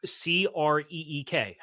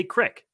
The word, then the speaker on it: creek: And like C R E E K. How do you pronounce it? – Creek. – It's some people up here say crick. – I've heard crick. I've heard that.